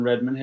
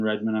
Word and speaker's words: Redmon: On Instagram, my Instagram handle is Nathan Redmond Health Coach, and my last name is spelled R-E-D-M-O-N. That's the one thing people always get wrong. It's R-E-D-M-O-N. So Nathan Redmond Health Coach.